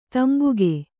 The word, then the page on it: ತಂಬಾಕು: tambāku